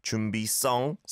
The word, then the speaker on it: 준비성: 준비성, 성, as our producer Minnie said, say it with a 성 시옷 there.